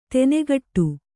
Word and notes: ದನಿಗೊಡು: kannada

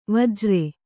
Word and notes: ವಜ್ರಿ: vajri

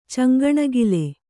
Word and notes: ಚಂಗಣಿಗಿಲು: caŋgaṇigilu

